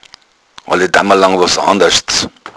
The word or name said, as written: damalang